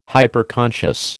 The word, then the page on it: Hyperconscious: American English